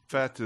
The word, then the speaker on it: fete: Fete is like a party you throw. Yeah, fete is the pronunciation, I believe. They said feet in the film for sure.